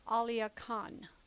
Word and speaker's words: Alia Kahn: Alia Kahn.